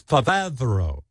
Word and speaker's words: Favreau: It's the actual pronunciation is Favreau, but the problem with John Favreau is he looks like Benjy, but he's a genius like Einstein.